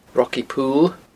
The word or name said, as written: Rocky Pool